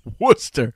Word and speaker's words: Worcester: And that's in Worcester, pronounced Worcester, this weekend.